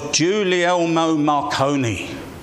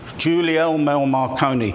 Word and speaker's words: Guglielmo Marconi: You know, the young Italian experimenter, whose name I have trouble pronouncing, bear with me, was Guglielmo Marconi. Guglielmo Marconi.